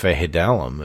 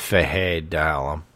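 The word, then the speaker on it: Fejedelem: I think it's Fahedelem, if I'm not mistaken. I know I'm saying it wrong, but I do believe it's Fehe, not Feje. It's Fahedelem. Sheldrick Redwine and Elijah Campbell there.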